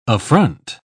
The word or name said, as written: affront